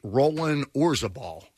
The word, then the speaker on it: Roland Orzabal: Press, am I saying this right? Roland Orzabal. – Yeah, from Tears for Fears.